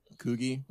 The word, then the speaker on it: Coogi: Coogie.